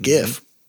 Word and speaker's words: GIF: Graphical interchange format, GIF. Mm-hmm. Hard G.